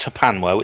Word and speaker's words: HPANWO: It's pronounced HPANWO. It's spelled H-P-A-N-W-O, and that stands for Hospital Porters Against the New World Order.